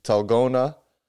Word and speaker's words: Dalgona: It's typically pronounced with the T instead of a D. So, Talgona. Do you guys recall this? You recall the Talgona? – No. – Talgona. I believe it's with the accent on the end.